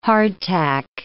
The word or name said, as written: hardtack